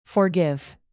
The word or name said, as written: forgive